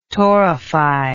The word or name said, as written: torrify